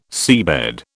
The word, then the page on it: seabed: British English